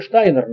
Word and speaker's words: Штайнер: В 2014 году он поехал к Штейнеру, вообще Штайнер надо правильно произносить.